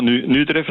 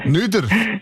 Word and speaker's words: Nüdorf: Ja, Nüdorf natürlich. – Nüdorf? – Nüdorf. – Nüdorf. – Genau. – Das ist immer so schön in den Dialekt, wie die Ortschaften so völlig anders ausgesprochen werden.